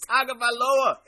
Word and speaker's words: Tagovailoa: It's Tagovailoa. It's Tagovailoa. There's an N sound in there.